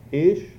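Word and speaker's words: Ish (איש): In the Hebrew, the word for man is I-Y-S-H. Ish, if you pronounce it that way. And in the Hebrew for woman, it's Isha. I-S-H-A-H-A-H.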